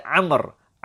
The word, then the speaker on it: Amr: We also learned about the difference between the name Umar and Amr. Amr, the name Amr, Ayn, Mim, Ra, and we add in a waw at the end to show that this name is pronounced as Amr, whereas Umar does not have that waw at the end.